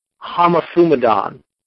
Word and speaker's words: homothumadon: Here in the book of Acts, regarding the situation that we've been discussing, is the Greek word homothumadon. Homothumadon. It's spelled H-O-M-O-T-H-U-M-A-D-O-N, transliterated.